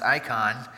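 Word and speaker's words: icon (εἰκών): By the way, the word image in the Greek is pronounced icon.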